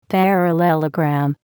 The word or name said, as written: parallelogram